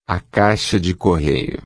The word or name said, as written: caixa de correio